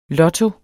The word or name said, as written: lotto